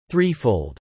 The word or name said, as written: threefold